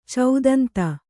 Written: ಚೌದಂತಿ

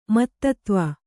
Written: ಮತ್ತತ್ವ

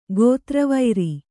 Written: ಗೋತ್ರವೈರಿ

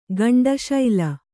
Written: ಗಂಡುಶಿಲೆ

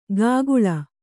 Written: ಕಾಗುಳ

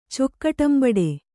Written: ಚೊಕ್ಕಟಂಬಡೆ

